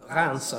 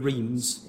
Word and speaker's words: Reims: And Reims, as I think you pronounce it in French, but Reims, if you're English, had a Catholic seminary.